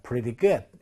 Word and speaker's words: pretty good: 혹은 t 사운드가 약해지면 pretty에서 리을로 발음 나기도 합니다. pretty good 이렇게 발음하죠.